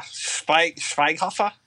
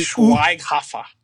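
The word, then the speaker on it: Schweighöfer: How do you say his last? He's German. Yeah. Matthias, like, Schweighofer or something like that. – Yeah. – Matthias Schweighofer. – Yeah. Schweighofer? – Yeah, but it's like an umlaut, isn't it?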